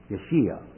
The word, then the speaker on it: Yeshua: Well, in Hebrew, the J is pronounced as a Y. For example, Joshua, Yeshua. It's pronounced as a Y. But we have here this tetragrammaton, J-H-V-H, or Y-H-W-H.